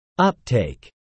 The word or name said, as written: uptake